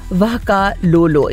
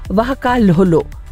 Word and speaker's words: vakalolo: वाकालोलो.